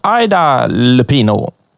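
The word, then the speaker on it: Ida Lupino: Fast det uttalas Aida Lupino.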